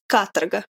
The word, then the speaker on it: Katorga: Oh, Katorga. – Katorga. – Katorga. – the O is basically an A. It's like Katarka. And the emphasis is on the first A. So it's Katarga.